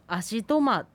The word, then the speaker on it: アシトマ: でこのアシトマっていう単語なんですけれども、ちょっと例外のアクセントをつけてしまうのを忘れてしまったんですけれども、アシトマという単語はトが一番高いです。